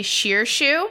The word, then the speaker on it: Shirshu: So the creature that June is riding is called a Shirshu. – Mm-hmm. How do they spell that? – S-H-I-R-S-H-U. – Okay. All right. Shirshu. – Shirshu.